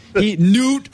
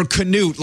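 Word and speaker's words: Knute: Newt or Canute. Like, who calls him Canute Rockney?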